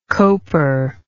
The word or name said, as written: coper